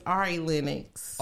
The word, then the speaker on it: Ari Lennox: This song is called Got Me by Aria Lennox, Omen, T.Y. Dollar Sign. Get her name right. Her name is Ari Lennox. – Ari Lennox. What I say? – You said Aria Lennox.